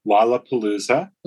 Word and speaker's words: Lollapalooza: In response, and despite that apology, the organizers of Lollapalooza, how do you say this? – Lollapalooza. – Lollapalooza.